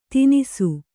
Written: ತಿನಿಸು

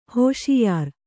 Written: ಹೋಷಿಯಾರ್

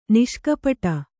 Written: ನಿಷ್ಕಪಟ

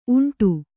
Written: ಉಂಡಿ

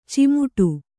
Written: ಚಿಮುಟು

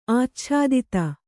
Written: ಆಚ್ಛಾದಿತ

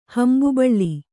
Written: ಹಂಬುಬಳ್ಳಿ

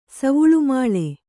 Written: ಸವುಳುಮಾಳೆ

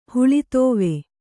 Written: ಹುಲಿದೇವ

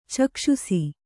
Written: ಚಕ್ಷುಸ್ಸು

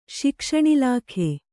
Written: ಶಿಕ್ಷಣಇಲಾಖೆ

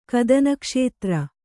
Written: ಕದನಕ್ಷೇತ್ರ

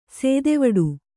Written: ಸೇದೆವಡು

